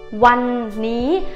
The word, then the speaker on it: วันนี้: Hôm nay thì tiếng Thái đọc là วันนี้วันนี้ one ní ở trên bản duyên ghi là one ní